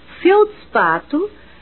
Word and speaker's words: feldspato: E na palavra feldspato, vamos observar que a primeira sílaba tem cinco fonemas, pois as cinco letras são pronunciadas.